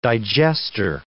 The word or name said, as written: digester